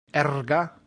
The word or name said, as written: erga